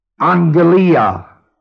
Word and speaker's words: aggelia: The letters E-U mean W-E-L-L. Well. A-G-G-E-L-I-A means a message. A well message. A well message. The word A-G-G-E-L-I-A appears singularly like that, and it is pronounced Anglia, Anglia, transliterated literally into the word evangel, E-V-A-N-G-E-L, carried over further into another word, what would it be?